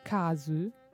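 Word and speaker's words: kazu (粕): In giapponese l'ideogramma per scrivere scarti, che si pronuncia kazu, è composto di radici che significano bianco e riso.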